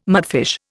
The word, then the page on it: mudfish: Indian